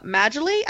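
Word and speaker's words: Majolie: Maggiolie, I believe it's pronounced, it's M-A-J-O-L-I-E, and her first project was Pinocchio, which is the next movie we're going to do.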